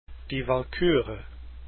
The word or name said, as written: Die Walküre